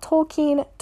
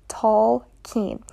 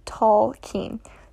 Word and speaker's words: Tolkien: It's not Tolkien, it is Tolkien, and it has Germanic origins, so the correct pronunciation is Tolkien.